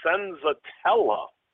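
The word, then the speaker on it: Senzatela: You know, also, you know, the guy, if you want to dig even farther down, Antonio Senzatella.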